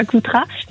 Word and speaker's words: Coutras: Alors je ne sais pas si on dit Coutras ou Coutras. – Non, je crois qu'on prononce pas le S, mais sans doute. Ah voilà, je suis à Coutras et en fait moi je me trouve devant la maison d'hôte que gère aujourd'hui cette ancienne avocate.